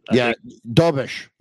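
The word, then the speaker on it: Dobeš: I think that, I don't even know how to pronounce his name, but Dobbs. – Dobish. Jakub Dobish.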